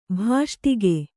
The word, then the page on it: ಭಾಷ್ಟಿಗೆ: bhāṣṭige